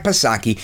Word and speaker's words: Psaki: That's right, Jen Pacircleback Psaki. Silent P, of course.